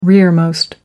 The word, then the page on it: rearmost: American English